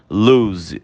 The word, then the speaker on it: lose: Gente, olha, repara na pronúncia dessa palavra. A gente fala como se ela tivesse dois S's. Loose, loose. É diferente da palavra anterior, que é o verbo perder, que a gente pronuncia lose, ok?